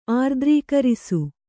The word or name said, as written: ಆರ್ದ್ರೀಕರಿಸು